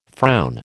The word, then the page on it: frown: American English